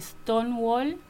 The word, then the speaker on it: Stonewall: Stonewall, ¿no?